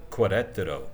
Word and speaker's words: Querétaro: They looked up on YouTube, the lots of videos you can see, of Querétaro, Q-U-E-R-E-T-A-R-O, Querétaro, probably not pronouncing that perfectly.